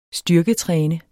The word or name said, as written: styrketræne